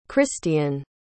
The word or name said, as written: Krystian